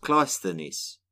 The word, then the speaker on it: Cleisthenes: Clisthenes, often pronounced Cleisthenes, had some very clear ideas about how Athens should be ruled.